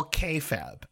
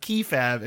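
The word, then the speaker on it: kayfabe: Before I use this word again, I have had listeners complain to me, so I'm going to pronounce this correctly. It's all K-fab. I typically pronounce it key-fab and people get very mad at me.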